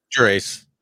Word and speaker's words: Gerace: Gerace.